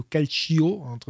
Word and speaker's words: calcio: Parce qu'en France, j'entends souvent, alors déjà prononcer le calcio, donc déjà on n'entend pas le i, hein, le i est là pour faire tch, on n'entend pas. Donc calcio. – Calcio, ok. – Tcho, pas cio, tcho.